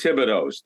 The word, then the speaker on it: Thibodeau: When you lived in Rumford, Maine, there are 100 Thibodeaus. That's the way you pronounced it. When I came down here, everybody kept saying Thibodeau.